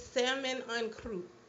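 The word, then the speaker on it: saumon en croûte: And it's salmon en croûte. That's how it's pronounced. the proper pronunciation, but it means Sam and Wellington.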